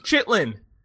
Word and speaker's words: chitterlings: That is how it's spelled. It's not how it's pronounced. – Chitlin. – Chitterlings. – Stop. Chitlin. Not chitterling, motherfucker.